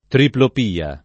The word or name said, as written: triplopia